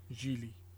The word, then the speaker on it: Gigli: It's Gigli. It's pronounced. It's based on an the Italian pronunciation, Gigli.